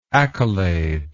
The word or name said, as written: accoladed